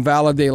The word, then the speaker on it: Valade: Now, Mike, butcher his first name, so I just call him Valade like Holiday.